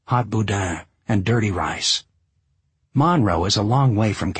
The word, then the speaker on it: Monroe: The Cajun fried chicken stand in Monroe, accent the first syllable, where I'd stopped for gas, determined the direction of the next several days.